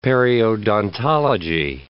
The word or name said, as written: periodontology